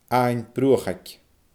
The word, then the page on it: Abhainn Bruachaig: Scottish Gaelic